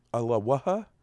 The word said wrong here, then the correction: alebrije: Um, an alebrije, I believe, is the pronunciation.